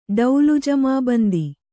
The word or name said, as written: ಡೌಲುಜಮಾಬಂದಿ